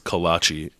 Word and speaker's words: kolache: According to the internet, it's pronounced kolache.